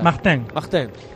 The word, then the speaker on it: Martin: ¿Cuál es la pronunciación correcta? ¿Mactán? ¿Cuál sería? – Mactán. Guillaume Martin.